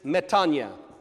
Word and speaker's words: metanoia: Some people have pronounced that metanoia. Do not do that ever again. Don't do it. Don't. No. If somebody says uses metanoia in front of you, tell them, excuse me, no, sorry. It's pronounced metanoia.